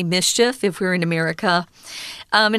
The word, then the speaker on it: MSCHF: Well, this group called MSCHF, or in English, we would actually just pronounce it mischief because it kind of makes that sound if you say those letters together quickly.